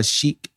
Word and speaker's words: chic: It's pronounced chic, not sick.